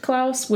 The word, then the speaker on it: Klaus: It's Claus, I suppose, supposed to be pronounced Klaus, which is how they pronounce it in the movie.